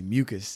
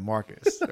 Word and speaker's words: Marcus: Like, it was to the point where my boss at work was calling me Mucus instead of Marcus, right?